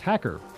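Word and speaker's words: hacker (ХАКЕР): It's pronounced hacker. The X is pronounced H, and the P is pronounced R, and I think you can fill in the rest.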